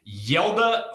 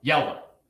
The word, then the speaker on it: Hjalte: It's pronounced Yelda. I've double checked it 1,000 times. In fact, here, if you want, I got my handy dandy Browns pronunciation guide here somewhere. – I could have tried 12 different pronunciations. I would never have gotten to Yelda. For anyone wondering, it is spelled H-J-A-L-T-E.